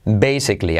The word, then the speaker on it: basically: Basically. Ahí la tienes. basically Así se dice. Basically. Basically. Con letra clave L.